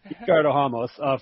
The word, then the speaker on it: Ricardo Ramos: First of all, I believe it's called – it's pronounced Ricardo Ramos. Ricardo Ramos. First of all, American butchering his name.